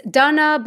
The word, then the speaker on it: Dana: My name is Dunna, but it's spelled Dana, mm-hmm. D-A-N-A,